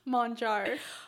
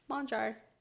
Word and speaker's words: Monjar: Monjar. – I apologize. – It's okay. People always assume that my last name has some fancy pronunciation, but it's just like it's spelled Monjar.